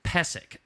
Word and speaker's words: Pysyk: It's P-Y-S-Y-K. Oh, no. – Yeah, P-Y-S-Y-K. – Yeah, P-Y-S-Y-K. But it's pronounced Pesek.